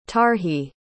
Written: tarhe

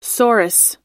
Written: sorus

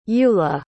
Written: eulah

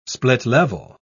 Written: split-level

